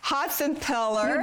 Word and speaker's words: Hotsenpiller: Hotsenpiller.